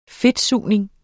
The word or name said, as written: fedtsugning